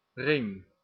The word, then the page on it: basket: /ˈbæs.kɪt/